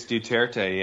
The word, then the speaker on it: Duterte: How do you pronounce his name? Duterte? Is that it? – I think it's Duterte, yeah.